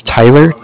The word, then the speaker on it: Tyler: My name is Tyler, T-Y-L-E-R. – Mm-hmm. – And I'm calling with Cardiff. It's spelled C-A-R-D-I-F-F, as in Frank.